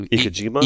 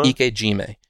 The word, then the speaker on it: ikejime: So ikejima, ikejime.